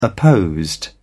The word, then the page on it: opposed: British English